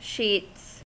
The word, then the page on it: sheets: American English